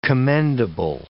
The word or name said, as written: commendable